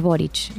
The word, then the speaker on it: Boric: Creo que es Boric la pronunciación.